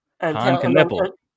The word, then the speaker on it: Knueppel: Knueppel.